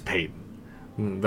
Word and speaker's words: Paton: Yeah, and it's George Payton, even though it's spelled Patton, it's Payton.